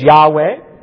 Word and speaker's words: Yahweh: They would say Jehovah. Probably a better pronunciation is Yahweh.